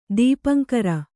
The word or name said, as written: ದೀಪಾಂಕುರ